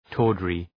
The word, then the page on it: tawdry: ‘tɔ:drı